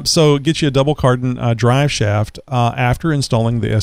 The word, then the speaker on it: cardan: And you'll need either CV or, and am I saying this right? Because I always get the sweater mixed up with it. The double cardan? Is that, you got it right.